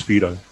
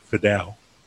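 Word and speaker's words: Fidow: Like, one name that Ken absolutely butchers is Alex Fido, so it's pronounced Fido, but he started pronouncing it Fidel, like 10 years ago when he was at Scots, and everyone says Fidel now. So they'll say, Tyrell Lomax been replaced by Alex Fidel, but it's actually Fido.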